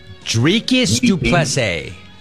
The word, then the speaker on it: Dricus du Plessis: Dricus du Plessis.